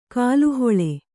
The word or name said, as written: ಕಾಲುಹೊಳೆ